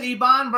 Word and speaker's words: Eban: So that's how we pronounce it, Eban Brown, right? – Eban Brown. – Eban. Okay. Okay. See, we wanted to get it right. – I would have pronounced it Eben. – Eben. Well, you can do that. Eben, Eban, or Eben.